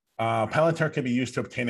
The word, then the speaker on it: Palantir: And it's about Palantir, if I'm pronouncing that right. – Palantir, yep.